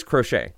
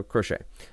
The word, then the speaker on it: Crochet: But anyway, it is Crochet. So Crochet. Garrett Crochet.